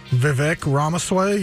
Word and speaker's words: Vivek Ramaswamy: Vivek Ramaswamy, your next president? – Ramaswamy.